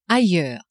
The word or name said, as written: ailleurs